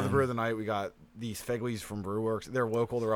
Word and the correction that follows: Fegley: It's F-E-G-L-E-Y for those who are listening. – Figley Brewworks. – It's Fegley's. – Fegley. That sounds more like it.